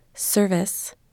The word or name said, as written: service